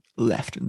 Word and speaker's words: Lieutenant: Bringing up the rest of the cast, we've got First Lieutenant William, was it Lieutenant or is it Lieutenant? – Left, the Royal Navy, Rob.